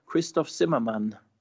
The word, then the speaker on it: Christoph Zimmermann: And please correct me if I'm wrong, Christoph Zimmermann. – Yes, that's correct. In German, Christoph Zimmermann. In English, more Christoph Zimmermann. I'm used to both pronunciations.